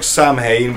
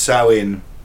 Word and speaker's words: Samhain: It looks like Samhain, but it's pronounced Samhain.